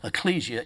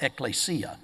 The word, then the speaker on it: ecclesia: A lot of people uh, pronounce that ecclesia, it's actually pronounced ecclesia.